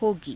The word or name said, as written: hogii